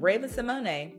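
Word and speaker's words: Raven-Symoné: Raven Simone, because that's how you pronounce her name.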